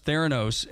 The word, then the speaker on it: Theranos: Did I pronounce it? – Theranos.